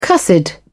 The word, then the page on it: cussed: British English